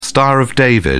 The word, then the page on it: star of david: British English